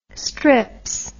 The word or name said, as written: strips